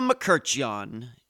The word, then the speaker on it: Mkrtchyan: Irma Mkrtchyan, yep, that's how you pronounce her last name, came to the U.S.